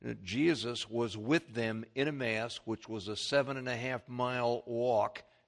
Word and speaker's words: Emmaus: Now, I've been pronouncing the word Emmaus wrong for years. If you notice, that's a short E. It should be Emmaus.